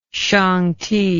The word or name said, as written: shangti